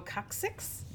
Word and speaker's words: coccyx: Oh, coccyx? – Yeah.